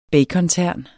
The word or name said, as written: bacontern